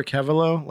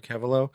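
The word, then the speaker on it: Keveloh: Yeah, Trevor Kevelo. Last name is spelled K-E-V as in Victor, E-L-O-H, all together, Trevor Kevelo.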